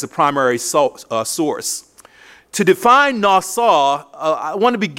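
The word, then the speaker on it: nasa (נָשָׂא): First of all, the Hebrew word for forgiveness is nasa. Nasa, N-A-S-A, N-A-S-A, pronounced nasa, N-A-S-A.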